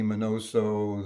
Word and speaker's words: Minoso: They had Minnie Minoso. They had, which rhymes with Spinoso, which is kind of a cool thing.